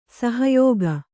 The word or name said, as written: ಸಹಾಯಕ